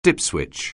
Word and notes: dipswitch: anglais

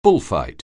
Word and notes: bullfight: anglais

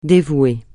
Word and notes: dévouer: francais

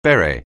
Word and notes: beret: anglais

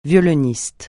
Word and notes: violoniste: francais

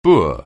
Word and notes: boor: anglais

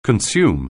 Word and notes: consume: anglais

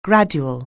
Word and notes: gradual: anglais

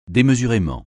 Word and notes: démesurément: français

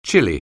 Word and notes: chilly: anglais

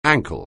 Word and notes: ankle: anglais

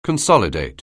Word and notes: consolidate: anglais

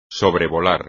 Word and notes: sobrevolar: espagnol

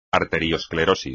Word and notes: arteriosclerosis: espagnol

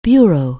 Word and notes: bureau: anglais

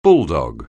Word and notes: bulldog: anglais

